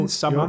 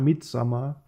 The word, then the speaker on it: Midsommar: Jeg har været i biografen og se, øh, ja, jeg ved ikke, om jeg udtaler det, er nok Midsommer, eller Midsommer, og på engelsk, jeg ja, ved, det er et A, Det, Jo, Midsommar. jo, Midsommar.